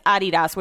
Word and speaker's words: Adidas: Like, the pronunciation is Adidas, which is a conversation that I had with Brett when he first moved here.